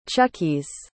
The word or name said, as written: chuckies